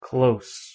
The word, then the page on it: close: adjective